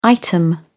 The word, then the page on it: item: British English